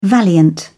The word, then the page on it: valiant: British English